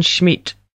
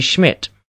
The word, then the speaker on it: Schmidt: My name is Wolfgang Schmidt. That's Wolfgang. – Wolfgang? Okay, how do you spell that? – Wolfgang. – Okay, G-A-N-G, uh-huh. – And my last name is Schmidt. – Schmidt, uh-huh. – S-C-H-M-I-D-T. – S-C-H-M-I-D-T.